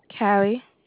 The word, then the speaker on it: Cali: Callie. – How do you spell that? – C-A-L-I. – C-A-L.